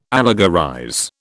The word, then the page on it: allegorize: British English